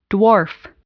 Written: dwarf

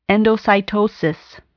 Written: endocytosis

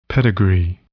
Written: pedigree

